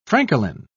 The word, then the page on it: francolin: British English